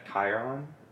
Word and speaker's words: Chiron: How do you say it? – Chiron. – Okay, Charon. – I say Chiron.